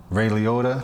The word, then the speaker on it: Liotta: Ray Liotta, I think that's how you say his name.